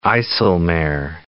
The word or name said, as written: IJsselmeer